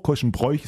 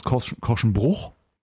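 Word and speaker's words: Korschenbroich: Was sagst du, Korschenbruch, Korschenbräuch? Ist das eine alte Philosophiefrage, ne? Ich glaube, man sagt offizielle Aussprache ist Korschenbruch.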